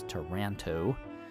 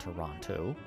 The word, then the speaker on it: Taranto: That's Taranto, not Toronto, as in, anyway, T-A-R-A-N-T-O. That's how they spell it.